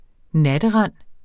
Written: natterend

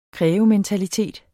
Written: krævementalitet

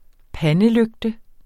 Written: pandelygte

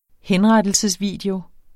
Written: henrettelsesvideo

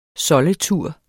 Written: soldetur

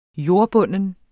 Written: JORDBUNDNE

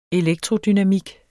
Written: elektrodynamik

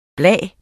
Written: bla

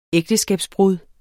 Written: ægteskabsbrud

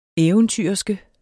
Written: eventyrerske

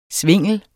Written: svingel

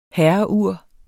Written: herreur

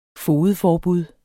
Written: fogedforbud

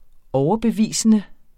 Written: overbevisende

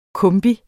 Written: kombi

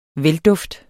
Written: velduft

